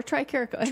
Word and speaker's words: Tricarico: Is it Tricarico or Tricarico? – Tricarico. – I've been saying it wrong all this time.